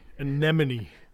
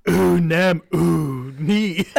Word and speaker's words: anemone: So the pronunciation is ooh-nem-ooh-nee. – No, it's anemone.